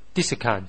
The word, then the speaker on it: discount: 例如，我们再首先说一下那个 discount 的那个发音啊，那个重音，那个重音在第一个音节 discount 的时候呢，它是个名词啊。